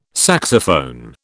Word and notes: saxophone: British English

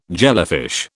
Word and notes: jellyfish: British English

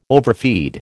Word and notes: overfeed: American English

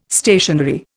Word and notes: stationary: Indian

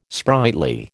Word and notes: sprightly: American English

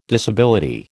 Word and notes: disability: American English

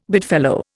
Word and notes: bedfellow: Indian

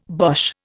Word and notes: Boche: Indian

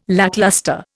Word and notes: lacklustre: Indian

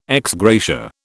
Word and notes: ex gratia: British English

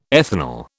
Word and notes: ethanol: British English

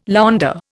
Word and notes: launder: Indian

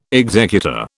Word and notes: executor: British English

